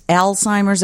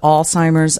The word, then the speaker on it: Alzheimer's: And it's Alzheimer's as opposed to Alzheimer's. I don't even know how to say it. – Either way is fine.